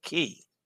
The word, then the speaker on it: Cay: K and Quay is always pronounced key.